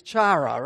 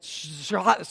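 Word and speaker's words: chara (χαρά): And it, you go into this, and it's like chara. It's not chara at all. But it is spelled C-H-A-R-A, and it appears 59 times in the New Testament.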